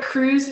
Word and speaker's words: Krz: Yeah, but Cruz is spelled K-R-Z. Yeah, Cruz.